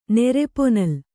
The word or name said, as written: ನೆರೆಪೊನಲ್